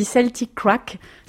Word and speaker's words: Celtik'Raic: Alors je me permets juste de te corriger, on dit Celtic Crack, ça s'écrit Celtic Crack, mais en fait c'est du gaélique. Ça veut dire un moment fun celtique, donc voilà, ça se dit avec l'accent Celtic Crack.